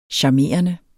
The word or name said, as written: charmerende